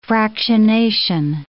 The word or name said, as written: fractionation